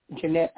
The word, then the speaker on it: Jeanette: And if you have any topics that you would like for me to discuss, you can Google my name, Jeanette Abney, which is spelled J-E-A-N-E-T-T-E. Last name is A-B-N-E-Y.